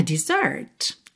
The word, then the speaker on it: dessert: Exatamente a mesma pronúncia, ou seja, o que você ouve é exatamente o mesmo som, mas é uma outra palavra que, inclusive, pasme, se escreve diferente, com dois S's. Mas não muda a sua pronúncia, de Z para S. Você não fala dessert, é mesmo dessert.